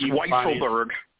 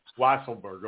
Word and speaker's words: Weisselberg: I mean, so I'm reading right now from the New York Times, and it says Alan Weisselberg, I'm sure if I'm pronouncing his name right, the Trump Organization. – I think it's Weisselberg. – Weisselberg, okay.